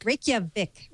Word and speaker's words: Reykjavik: No, Reykjavik. Am I pronouncing Reykjavik? – Reykjavik.